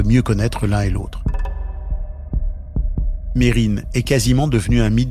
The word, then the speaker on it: Mesrine: Jacques Mérine, comme le prononcent ses proches, ou Mesrine, comme le disent de nombreux médias et policiers.